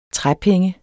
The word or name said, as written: træpenge